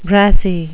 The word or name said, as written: brassy